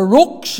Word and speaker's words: Rocks: They call it the Rooks down there. You know this Ulster Scottish thing? Well, that's the way the lingo is. It's known as the Rooks Mission Hall, but it's spelled R-O-C-K-S.